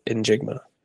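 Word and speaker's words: Njigba: That's how you say it. In Jigma.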